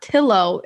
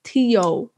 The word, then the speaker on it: Quarantillo: It's not Tillo. It's Tio. Like how quesadilla is pronounced with a Y. – Mm-hmm. – Like my last name. Two L's is Y.